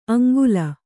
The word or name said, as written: ಅಂಗುಲ